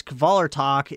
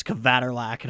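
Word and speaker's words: Kvelertak: So, speaking of bands that kick ass, Kvaler Tok is done writing new material. And, I heard that band's name pronounced as Kvaterlak, and I almost lost my shit.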